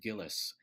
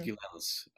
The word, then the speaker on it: Gilels: His manager, Saul Hurok, always called him Gilles, but it was Gilels.